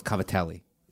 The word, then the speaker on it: cavatelli: Covadils. – Called Cavatelli. It's the way it's spelled.